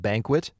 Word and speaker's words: BNQT: It's called Banquet. That's spelled B-N-Q-T, by the way.